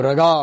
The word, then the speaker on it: raaga: I see raga spelled R-A-G-A, and another time I see it here says R-A-A-G-A. – Just pronunciation. – Just pronunciation. – See, if you do not put two A's, then you might say raga. – Yes. – Okay, or raga or something, you know, you don't know. To distinguish and to make sure that you understand the pronunciation right, it's raga. So the first A is long, mm-hmm. Second A is short.